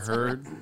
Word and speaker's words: Heard: Oh, Amber Heard, turd, rhymes with turd.